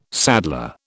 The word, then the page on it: saddler: British English